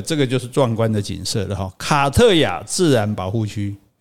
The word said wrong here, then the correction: 卡法亞特: 卡法亚特吧？ – 哎，卡法亚特，对啊，我怎么会念成卡特亚吧？